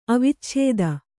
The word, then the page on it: ಅವಚ್ಛೇದ: avacchēda